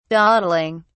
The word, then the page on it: dawdling: British English